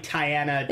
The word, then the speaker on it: Tiana: Oh, Tiana, why was I calling her Diana? – Because it's pronounced, it's like Tiana. – It's Diana. – Tiana. Okay.